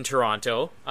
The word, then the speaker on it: Toronto: So they start out in Toronto, and eventually we'll move to Kamloops. So, you pronounce both T's. What is wrong with you? – I know.